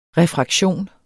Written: refraktion

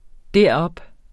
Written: derop

